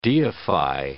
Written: deify

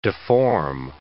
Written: deform

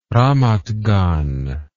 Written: ramat gan

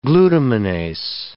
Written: glutaminase

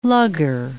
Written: lugger

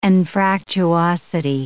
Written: anfractuosity

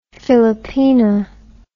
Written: philippina

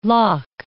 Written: lough

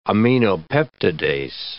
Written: aminopeptidase

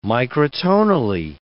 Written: microtonally

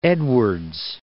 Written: Edwards Plateau

